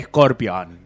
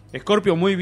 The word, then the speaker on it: Scorpio: Vamos con Scorpio, ¿cómo viene Scorpio? – Muy bien, qué bien que lo venís pronunciando. – Sí, viste, después se decía Scorpion.